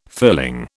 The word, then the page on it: filling: British English